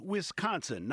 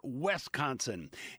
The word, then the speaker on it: Wisconsin: And the Badger State is Wisconsin, not Wisconsin.